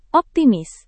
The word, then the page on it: optimis: Indonesian